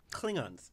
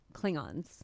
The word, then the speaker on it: Klingons: Okay, but this word is Kilgon. No, that's not correct. – Klingons. – Is that really how you pronounce yes.